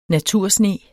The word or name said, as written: natursne